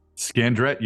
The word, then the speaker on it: Scandrette: Scandrette, you got it.